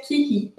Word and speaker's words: Curie: Como é em francês, é, é se pronuncia que ri.